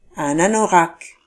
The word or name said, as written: anorak